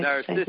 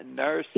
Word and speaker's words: Narcissus: That it's, that Narcissus, I don't know if that's the way Narcissus. – To say his name. – Yeah, Narcissus.